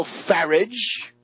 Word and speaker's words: Farage: Nigel Farage, rhymes with garbage, and Boris Johnson, the blonde pretty boy who vaunted himself as Thatcher's favorite journalist.